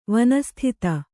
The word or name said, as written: ವನಸ್ಥಿತ